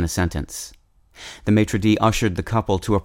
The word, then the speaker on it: maître d': For the record, the plural of maître d'hôtel is spelled with an S at the end of the word maître, but that S is silent as it would be in French, whereas the plural of maitre-DEE is maitre-DEEs, simply adding an S after the apostrophe.